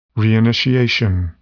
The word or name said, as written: reinitiation